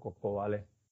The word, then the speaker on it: Cocobalé: Coco con K, vale con e, acento en la E, todo junto, Coco Vale.